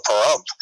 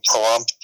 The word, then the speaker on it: Pahrump: to Pahrump.